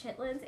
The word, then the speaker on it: chitterlings: It's spelled chitterlings, but it's chitlins.